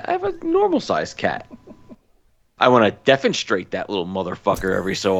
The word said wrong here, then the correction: defenestrate: Is that how you pronounce it? I thought it was defenestrate. It is. Okay.